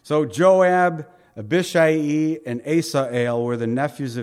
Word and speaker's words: Abishai: And there were three sons of Zeruiah there, Joab and Abishai, I think is really how you say it, Abishai, and Asael. These are the Hebrew pronunciations.